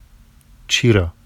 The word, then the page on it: ʧirɑ: Vafsi